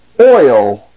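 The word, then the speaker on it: oi (οι): The next diphthong is omicron iota. These two sounds, O-E, O-E, O-E, O-E, until they are combined to pronounce oi, as the diphthong is pronounced in the English word oil, oi, oil.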